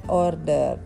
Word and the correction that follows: ordered: Hmm, pas sebelumnya banyak kedengeran T pas kok harusnya D. – Nah, ordered.